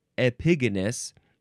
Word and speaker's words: Epigonus: Epigonus. Yeah, that's how you say it.